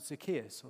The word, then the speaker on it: Zacchaeus: Zacchaeus. Uh, that's how I pronounce his name. I know some people call him Zacchaeus or something like that. Zacchaeus, I call him.